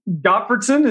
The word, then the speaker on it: Gottfredson: Gottfredson is how I say it, but right. Who knows if that's even right.